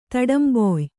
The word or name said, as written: ತಡಂಬೊಯ್